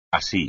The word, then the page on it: así: espagnol